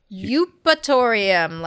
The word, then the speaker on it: Eupatorium: Eupatorium, like euphoria, EUPATORIUM.